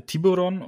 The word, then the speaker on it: Tiburon: Ähm, Tai kann auch den Namen des Auftragsgebers tatsächlich mitteilen und er heißt, also ich würde ihn auch wieder amerikanisch, Tyberon, so würde ich es amerikanisch aussprechen. – Hm? – Man spricht ihn ja hier sehr deutsch aus. – Äh, ja, also Genau, Tib äh, Tiburon, äh, weil das äh, spanischer Name eigentlich ist.